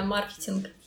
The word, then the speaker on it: маркетинг: Маркетинг или маркетинг? – Ну, вообще э, принято и так, и так, но правильно маркетинг маркетинг.